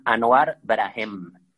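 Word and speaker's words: Anouar Brahem: Este, este se llama, es un músico tunecino que se llama Anuar Brahem. Se escribe A-N-O. U-A-R, Anouar y aparte, Brahem, con H, Brahem. Anuar Brahem.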